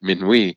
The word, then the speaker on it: Minuit: Americans traditionally pronounce his name Minuit, as you did. He was French background and would have probably pronounced it Minuit.